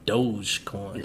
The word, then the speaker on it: Dogecoin: A couple days later, hey man, this apparently it's pronounced Dogecoin. – Okay, yeah, that makes sense. The E, yeah, Dogecoin.